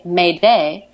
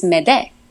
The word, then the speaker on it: m'aide: So it's the French word for help me is M apostrophe A-I-D-E, and that's pronounced aide. So it's M apostrophe aide, m'aide. So to say help me in French, it's m'aide, m'aide.